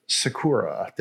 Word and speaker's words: Sakura: So tell me about the Nissan Sakura. Did I say that right? – You did.